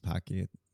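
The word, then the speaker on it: Paqui: In, I think it's pronounced Pocky. I think it's P A Q U I.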